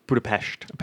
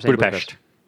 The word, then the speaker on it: Budapest: Exactly. It's Budapest. – Budapest. – Didn't know that until Black Widow came out, and I was like, oh, I've been saying it wrong this whole time.